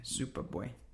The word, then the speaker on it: Supa Bwe: S-U-P-A space B-W-E. – B-W-E? – Yeah, Supabue.